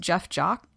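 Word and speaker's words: Jeph Jacques: It's written by, I think it's pronounced Jeff Jacques. – I think that's right. – J E P H is how Jeff spelled, but yep.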